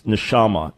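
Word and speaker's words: neshama (נשמה): By the way, you had the words pronounced right, but it's neshama, not neshama.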